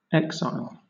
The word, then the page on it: exile: British English